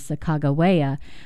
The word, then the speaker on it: Sacagawea: and the Hidatsu pronunciation is Sacagawea, but she's one of the first women of color that I learned about in school.